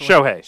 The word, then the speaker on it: Shohei: Shohei Sho- Shohei.